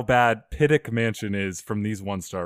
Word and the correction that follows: Pittock: I don't even know if I pronounced it right. Is it Piddick or is it Pittock? – Pittock. – Oh, Pittock.